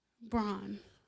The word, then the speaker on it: Braun: Braun. Like the paper towel.